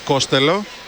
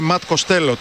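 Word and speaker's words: Costello: Πώς προφέρει το όνομά σου, λέει Ματ Κοστέλο. Τότε λέει γιατί σε φωνάζουμε έτσι, γιατί λέει κάποτε σε έναν δημοσιογράφο είχα πει Κόστελο γιατί του φάνηκε πιο εύκολο. Δηλαδή ο ίδιος ο παίκτης μας μπέρδεψε.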